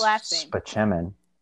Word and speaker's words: Spaceman: But it's pronounced Spachemin.